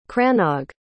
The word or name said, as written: cranog